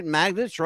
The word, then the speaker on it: Magdich: Brenda Lee Magdich is, and I hope I'm saying that right, Magdich, right? – Magdich. – There you go.